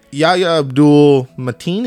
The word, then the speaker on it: Yahya Abdul-Mateen: Like, Yahya Abdul-Mateen, is that how you say his name?